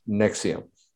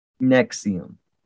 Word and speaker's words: NXIVM: The NXIVM is actually pronounced Nexium. – Nexium.